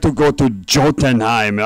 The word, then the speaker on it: Jotunheim: that story is going to be about if Odin had lost to the king of Jotunheim. – Yeah, I'm sorry, I pronounced the word the way it looks. – Jotunheim.